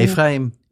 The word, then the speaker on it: Ephraim: Hoe spreek je naam op? Efrem? Hoe, hoe... – Efraim. Efraim. Ja hoor.